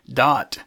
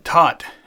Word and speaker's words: Todt: but had quoted individual Kirsten Dott, Tott, I guess is how you say it, T-O-D-T.